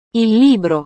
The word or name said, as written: il libro